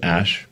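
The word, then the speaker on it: Æ: Um, so, I mean, it's just X, the letter X, and then the AE is like pronounced Ash.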